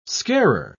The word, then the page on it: scarer: British English